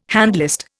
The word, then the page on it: handlist: Indian